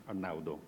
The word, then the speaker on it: Arnaudo: Actually, the pronunciation of my name is Italian, and that was very good. It was perfect. Arnaudo.